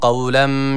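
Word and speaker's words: Kavlem (قولا): Kavlen kelimesine dikkat çekecektik. – Selamun kavlen. – Yani kavlen şeklinde okunuyor. – Selamun kavlem. Kavlem kaf. Yani kef harfi burada yok.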